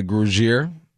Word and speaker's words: Grugier: They got Grugier Hill at the linebacker spot.